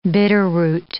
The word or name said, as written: bitterroot